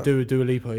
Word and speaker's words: Dua Lipa: Yeah, Dua Lipa, yeah.